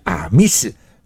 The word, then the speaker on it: 阿密斯: 然而在卑南族所谓的北方，也发音为叫阿密斯，因此从阿密斯而来的就是我们的阿美两个字了。